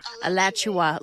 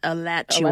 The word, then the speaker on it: Alachua: Alachua, like Joshua, but Alachua.